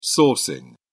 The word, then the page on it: sourcing: British English